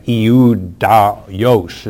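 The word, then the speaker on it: Ioudaios: Eudaios is how it's pronounced in the Greek.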